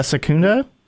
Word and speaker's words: Secunda: Secunda. – Yeah, Secunda.